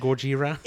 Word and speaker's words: Gojira: It's actually pronounced Gojira.